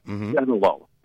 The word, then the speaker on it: Sinaloa: The Sinaloa cartel, Sinaloa, that's how you say it.